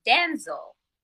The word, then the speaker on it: Denzel: He said, we've been pronouncing his name wrong all this whole time. He said, but he just let us do it because he just was like, okay, fine. But it's Denzel. – Denzel. – Denzel and not Denzel.